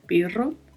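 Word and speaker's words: pero: Perro, que é uma conjunção adversativa, significa mas, porém, e eu chamo a atenção aqui para a pronúncia do R, que tem um vibrato, perro.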